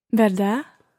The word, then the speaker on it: verdad: Verdad? – It's almost got a silent D on the end.